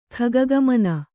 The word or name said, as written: ಖಗಗಮನ